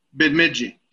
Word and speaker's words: Bemidji: Bemidji. Did I say it right? – Oh, that's right on the money, buddy.